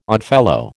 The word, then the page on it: Oddfellow: American English